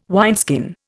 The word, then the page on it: wineskin: Indian